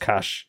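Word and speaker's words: Kaş: There's a place called Kash, right, in Turkey, spelled K-A-S, but it's pronounced Kash.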